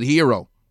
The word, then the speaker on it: gyro: But the greatest, G-Y-R-O, gyro. If you're Greek, you can pronounce it hero. Geg isn't the egg. It's a gyro to me because it has a G-Y-R-O.